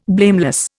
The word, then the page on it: blameless: Indian